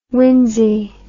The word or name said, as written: winsey